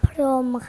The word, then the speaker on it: พรหม: ที่มีหอหีบที่ไม่ออกเสียงที่อยู่กลางคำค่ะค่ะคำศัพท์คือคำว่าพรหมครับ